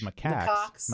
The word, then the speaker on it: macaque: It's macaque, isn't it? Because it's spelled C A C Q U E, right at the end.